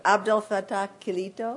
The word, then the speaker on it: Abdel Fattah Kilito: In 2010, he published his translation from the French of a novel by the Moroccan writer, I meant to ask you how to pronounce this, and I forgot, Abdel Fattah Kilito. – That's good.